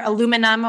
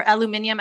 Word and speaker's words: aluminium: Risk factors for this include tobacco, occupational exposures, including workers in dye manufacturing and rubber, aluminum, or aluminium, as you might say in the UK.